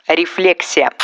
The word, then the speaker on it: рефлексия: Точнее, на обследование идите обязательно, а вот перенимать неправильное ударение, принятое в медицинской среде, не нужно рефлексия. Еще одно заблуждение из мира профессиональных ударений. Психологи говорят «рефлексия», но лучше слушать словарь, если речь идет об орфоэпических нормах.